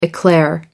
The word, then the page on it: eclair: American English